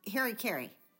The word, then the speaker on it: harakiri: It's hari kari. – Yeah. – I never knew that, and I also never knew that that's how it's spelled. H-A-R-A-K-I-R-I.